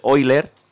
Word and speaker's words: Euler: Bueno, pues Leonhard Euler, se escribe Euler, todo el mundo hemos dicho Euler, por lo visto es Euler, vamos a llamarle Euler, que es realmente como se pronuncia.